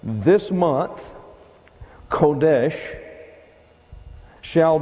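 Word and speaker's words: Kodesh (חודש): It looks like this. C-H-O-D-H-E-S-H. Now that is pronounced Kodesh.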